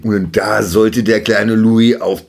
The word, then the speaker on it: Louis: Und zwar, er spricht sich nicht etwa Louis aus, in englisch-amerikanischer Art, sondern französisch, also Louis, nach irgendwelchen Ahnen aus dem Königshaus.